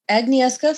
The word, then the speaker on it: Agnieszka: Agnieszka from Uprooted.